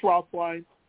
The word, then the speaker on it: Roethlein: The name is James Rothline. His name is R-O-E-T-H-L-E-I-N. It's not pronounced the way it's spelled.